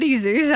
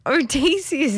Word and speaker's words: Odysseus: It's not Oddie Zeus. I don't know where that came from. Guys, dyslexia's catching up to me. But Odysseus. Odysseus is how you say it.